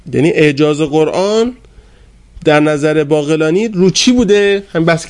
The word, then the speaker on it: باقلانی: کتاب اعجاز القرآن باقلانی، قافش رو باید یزدی تلفظ کرد، از رکائز اعجاز القرآن هست.